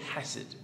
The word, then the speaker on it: chesed: You have to say it, it's a guttural, you have to say it chesed to say it correctly.